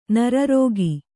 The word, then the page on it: ನರರೋಗಿ: nara rōgi